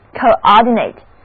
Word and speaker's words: coordinate: 那共同的秩序 coordinate 做动词讲的时候就是协调、调节，使大家按照一定的顺序共同去做什么 coordinate。这个时候字母 a 要读作它的本音，单词的重音在第二个音节。